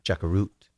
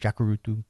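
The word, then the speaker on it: Jacurutu: Now, how do you pronounce that? – Jakarut. – Okay. – I pronounced it with the U on the end, Jakarutu, but is that what I can't remember exactly now in the book, was he drawn there, or did he get kidnapped?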